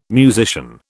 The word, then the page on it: musician: British English